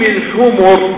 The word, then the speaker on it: الحمر: إذن فإذا نطق طبعا، إذا نطقت هذه الكلمة هنا لا يجوز إسكان الميم. ينهيانكم عن لحوم الحمر بالضم، لأن المراد هنا الحمير، الحمر جمع ماذا؟ جمع حمار.